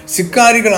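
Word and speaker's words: Sicarii: ഈ വാക്ക് ലാറ്റിൻ ഭാഷയിൽ സിക്കാരി എന്നും ഇംഗ്ലീഷിൽ സിക്കേരിയായി എന്നുമാണ് ഉച്ചരിക്കുന്നത്. ഇവിടെ ഈ സന്ദേശത്തിൽ തുടർന്ന് ലാറ്റിൻ ഉച്ചാരണം ഉപയോഗിക്കുകയാണ്.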